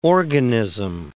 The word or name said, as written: organism